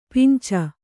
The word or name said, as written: ಪಂಚೆ